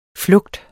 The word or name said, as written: flugter